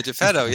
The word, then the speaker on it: Geppetto: And by the way, Pinocchio's dad's name, and I hope I'm saying this correctly, is Geppetto. – Yeah, right. That's right. Yeah, Geppetto.